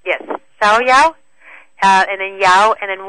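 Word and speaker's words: Xiaoyaowan: It's called Xiaoyawan, which is X-I-A-O, and then Y-A-O-W-A-N. It's called Xiaoyawan, or sometimes in some of the Chinese stores, they call it Free and Easy Wanderer.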